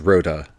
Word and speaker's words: Rotta: So the actor portraying Jabba says Rotta, so I am saying Rotta. It's R-O-T-T-A.